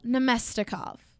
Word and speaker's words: Namestnikov: Vladislav Namestnikov. – Namestnikov. – Namestnikov. Yep. – That second N gets you.